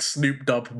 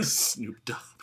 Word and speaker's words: Snoop Dogg: I think Snoop Dubb might be my favorite mispronunciation. – Snoop Dogg.